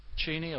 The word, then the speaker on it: Cheney: Cheney or Cheney, yeah, whichever.